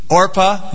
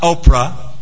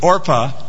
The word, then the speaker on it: Orpah: We have her two daughters-in-law after the death of her sons, Orpah, not Oprah, as my friend would like to pronounce it, but Orpah and Ruth.